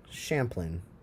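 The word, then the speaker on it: Champlin: Champlain? – Champlain. It's C-H-A-M-P-L-I-N. Champlain. Champlain.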